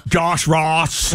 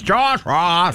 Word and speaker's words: Ross: Josh Ross on 101.9 POR. – You cannot say it like that. It's Josh Ross.